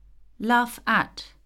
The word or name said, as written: laugh at